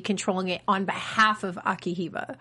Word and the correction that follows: Akihiko: Kayaba Akihiko.